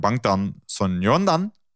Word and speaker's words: Bangtan Sonyeondan: BTS staat dan weer voor Bangtan Sonyeondan.